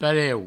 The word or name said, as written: pareu